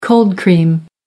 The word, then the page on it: cold-cream: American English